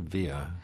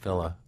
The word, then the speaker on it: Villa: Now, is it Villa or Villa? Because I thought in the Philippines it would have been pronounced Villa. – No, in Spanish it would be Villa, yeah. But he was used to being addressed as Villa.